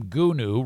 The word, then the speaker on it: Gunu: Gunu rhymes with who knew.